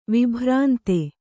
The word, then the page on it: ವಿಭ್ರಾಂತ: vibhrānta